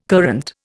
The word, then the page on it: courante: Indian